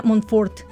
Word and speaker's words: Montfort: Y para ello tenemos de invitada a la licenciada en nutrición, la licenciada Ana Cristina Montfort. Buenas tardes, licenciada. – Buenas tardes, gracias por invitarme. Qué bueno que estás aquí con nosotros. ¿Sí pronunció bien Montfort o es Montfort? No, está muy bien así.